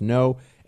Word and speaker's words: Ngo: Also follow him on Twitter at Andy N-G-O. His last name is N-G-O, pronounced no.